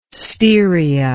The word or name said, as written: Styria